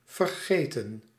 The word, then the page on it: overlook: American English; [oʊ.vɚˈlʊk]